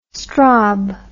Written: strob

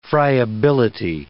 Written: friability

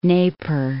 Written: Neper

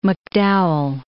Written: McDowell

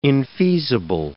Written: infeasible

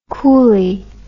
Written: coolly

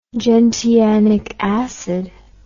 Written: gentianic acid

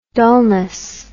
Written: dullness

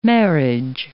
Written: marriage

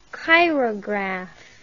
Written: chirograph

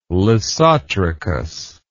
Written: lissotrichous

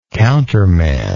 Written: counterman

